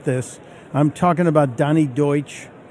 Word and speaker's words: Deutsch: I know some of you are going to call in and correct me and say that's pronounced douche. It's not. That may be what he is, but it is pronounced Deutsch.